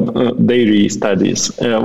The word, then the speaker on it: diary studies: Эм, я, на самом деле, меня очень сильно порезало по, по ушам э, Daily Studies или Daily Studies. Э, не знаю, как правильно прочитать, но читается как да. диарея э, э, studies. Вот, diary studies.